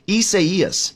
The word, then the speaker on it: Isaias: You said it beautifully, but I can't. Isaias. – Yeah, that's it.